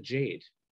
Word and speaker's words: Jagde: So anyway, I guess they kept the sound of the umlaut and then made the G like a Y. I don't get it, but we pronounce it Jade.